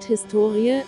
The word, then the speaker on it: Historie: Das Wort Historie, HS2IE, die Betonung liegt auf dem O, bezeichnet bis in das 18.